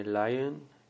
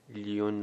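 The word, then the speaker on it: lion: خب بعدی کلمه لاین، لیون نخونید، تلفظ صحیحش لاین به معنی شیر.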